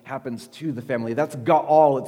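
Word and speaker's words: ga'al (גאל): And in the Old Testament, in Hebrew, there was a word that's pronounced ga'al. Can I hear all of you say, ga'al?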